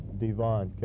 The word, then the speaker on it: DeVon: S-T-E-V-E-N? – Yes. – Your middle name? – Devon, capital D-E, capital V-O-N. – And your last name? – Nelson, N-E-L-S-O-N.